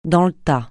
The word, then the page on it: dans le tas: francais